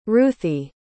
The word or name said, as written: ruthie